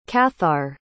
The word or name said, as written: cathar